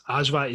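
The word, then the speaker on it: ajvar: Ajwa is the best.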